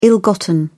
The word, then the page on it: ill-gotten: British English